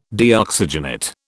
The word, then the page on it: Deoxygenate: British English